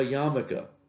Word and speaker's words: yarmulke: One last point for tonight, the idea of a yarmulke. What does the word yarmulke mean? You ever notice the silent letter in that word that's now pronounced?